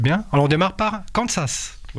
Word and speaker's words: Kansas: En France, d'où on dit Kansas, on ne prononce pas le S comme étant un Z.